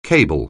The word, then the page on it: cable: anglais